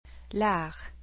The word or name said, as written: L'art